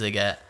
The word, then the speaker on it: Sziget: Sziget, is that how you pronounce it? I've only ever read it. – No, yeah, so it's Sziget in Budapest.